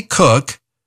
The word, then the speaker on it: Koch: Dr. Kathy Cook has recipes for better relationships with your adult kids. Her last name is spelled K-O-C-H. Pronounced cook, that's why I call it a cookbook.